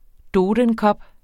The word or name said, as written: dodenkop